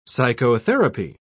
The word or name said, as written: psychotherapy